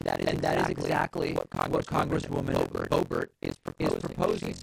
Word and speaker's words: Boebert: Radical Trumpist Republican Congresswoman Lauren Boebert, or Boebert, for that pronunciation. Our friend Ro Khanna, for example, prefers Boebert, which I kind of like, it has a satirical ring to it.